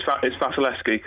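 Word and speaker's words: Wasilewski: So it's Vasilevsky, because Nigel corrected me.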